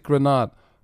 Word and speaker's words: Greenard: Ich weiß nicht, war es Grenard, weil der wird ja mit Doppel-E geschrieben. – Greener. – Eigentlich, ja, aber er spricht sich Grenard. Und apparently, habe ich wohl Greenard gesagt und dann irgendwann ja aber auch in der Mitte der Übertragung gesagt, oh shit, ja, der wird ja Grenard ausgesprochen. – Obwohl er eigentlich mit Doppel-E gesprochen wird.